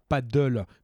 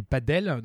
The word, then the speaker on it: padel: Donc, c'est pas du paddle comme la discipline où tu mets sur une planche et tu rames. Euh, c'est du padel. Donc, c'est espagnol. Ça s'écrit P-A-D-E-L.